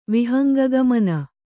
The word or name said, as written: ವಿಹಂಗಗಮನ